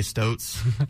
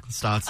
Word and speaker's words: Stotts: I didn't say Stotes. Stotts this time.